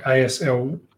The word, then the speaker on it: Aslaug: And most people would probably know her from Aslak or whatever, how you pronounce it in Vikings, Ragnar's second wife. – Aslak. – So, yeah, Aslak, Aslaug.